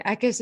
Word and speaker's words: Eckis: So you can find me just at Whitney Eckis, and Eckis is pronounced like a wet kiss.